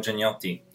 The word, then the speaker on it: Gianniotti: Yes, I'm Giacomo Gianniotti.